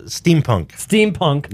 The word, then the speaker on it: steampunk: Is it steam engine? Am I saying it right? – Steampunk. Yeah.